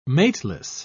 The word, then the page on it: mateless: British English